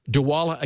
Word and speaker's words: Dwolla: D-W-O-L-L-A.